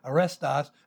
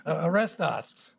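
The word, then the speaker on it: arestos (ἀρεστός): It's one of those words we learned in elementary Greek, arestos, and I always thought, like, that sounds like a policeman. Arestos. So that's how I remembered it. It's not spelled the same way. It is, of course, A-R-E-S-T-O-S, arestos, or estao, which means to be pleased.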